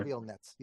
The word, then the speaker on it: Reviel Netz: Would that be the pronunciation of the author's name? – Raviel Netz. Yeah.